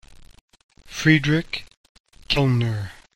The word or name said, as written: Friedrich Kellner